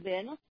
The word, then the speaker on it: Win: I, Vin, W-I-N, that's how you spell it.